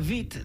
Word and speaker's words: Witt: Well, it was Katharina Witt, not Katharina Witt. Yeah. Because the W is pronounced like a V.